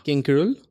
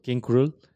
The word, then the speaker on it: King Krule: King Krul. King Krul.